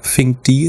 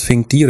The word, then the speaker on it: Finkd: Äh, und Sack postet jetzt neuerdings, also der war ja Ewigkeit nicht aktiv auf Twitter, aber unter dem, äh, ich glaube, Fink D ist immer noch ähm, sein ist das Finkdi oder wie spricht man das aus? Doch, ich glaube Finkdi, äh, also F-I-N-K-D,